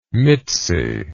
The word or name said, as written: mitzi